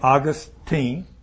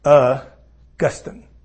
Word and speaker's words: Augustine: And so some people pronounce his name Augustine, and some people pronounce his name Augustine. And if you're scholarly and refined, you probably would say Augustine. If you're just a common person reading, you would say Augustine. What did I say? Anyhow, you'll hear his name pronounced both ways, okay? And it's not such a big misnomer, whichever way you do it. I've had seminary professors that pronounce it both ways, so don't get in a big deal over that.